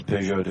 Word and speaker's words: Peugeot: Look at me trying to pronounce Peugeot the French way there. – Peugeot.